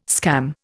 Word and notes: scam: Indian

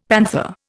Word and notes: panther: Indian

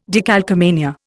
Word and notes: decalcomania: Indian

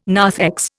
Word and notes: narthex: Indian

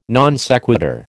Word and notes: non sequitur: American English

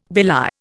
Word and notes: belie: Indian

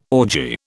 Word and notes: orgy: British English

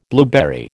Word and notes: blueberry: American English

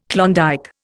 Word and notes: Klondike: Indian

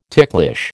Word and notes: ticklish: American English

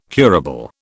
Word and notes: curable: British English

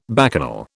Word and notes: bacchanal: British English

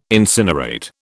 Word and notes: incinerate: British English

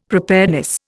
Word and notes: preparedness: Indian

